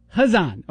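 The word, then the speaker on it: Khazan: How do you pronounce it, just out of curiosity? – I say Hazan. – Hazan. Okay, then I'm saying Hazan from now on.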